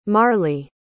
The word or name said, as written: marli